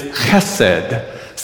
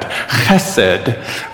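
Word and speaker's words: chesed (חֶסֶד): So the first syllable is in the back of your throat. Go, a little more. Oh, good. And then second syllable is sed. So go, chesed. Ready? Chesed. One more time. Chesed. Good. Chesed. One more time. Chesed. It's a little deeper.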